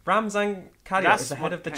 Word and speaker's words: Ramzan Kadyrov: Ramzan Gadyarov. I assume that's Russian? – Hang on, Ramzan Gadyarov. – How would you spell it? – Ramzan Kadyrov.